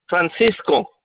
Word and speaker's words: Francisco: Francisco, like San Francisco City.